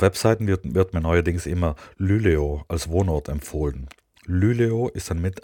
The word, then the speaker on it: Luleå: Luleå, Luleå, mit Betonung auf das Ü.